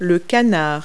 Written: duck

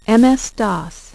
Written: MS-DOS

